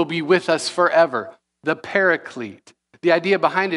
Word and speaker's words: paraclete: Sometimes you hear it, you hear it pronounced like this, the paraclete.